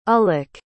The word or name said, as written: ullock